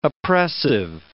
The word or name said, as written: oppressive